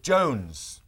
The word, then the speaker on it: Jones: Look, my surname, Jones. Look, how do you spell it? J-O-N-E-S.